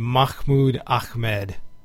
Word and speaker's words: Mahmoud Ahmed: And see, we've got King Sunny Ade, we've got Aerosmith, we've got, and I think this guy's name, if I'm pronouncing it right in the sort of Islamic way, would be Mahmoud Ahmed. – Yeah, that sounds about right.